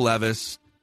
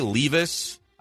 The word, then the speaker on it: Levis: So you got Bryce Young, CJ Stroud, Will Anderson Jr., Will Levis. You say Levis. I've heard Levis. – Yeah, it's one that you can go either way.